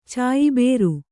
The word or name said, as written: ಚಾಯಬೇರು